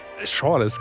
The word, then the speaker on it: Schorles: Wie spricht man es aus? Schorles? – Schorles, genau.